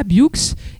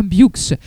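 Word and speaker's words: Buoux: Vous devez venir à Biux et pensez bien à prononcer le X. C'est pas Biu, c'est bien Biux.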